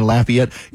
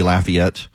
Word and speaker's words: Lafayette: For those of you in Louisiana right now listening in Lafayette, in Georgia, they pronounce it Lafayette. I've had to learn all these years of being in Georgia, but being a native of Louisiana, it is always, always going to be Lafayette.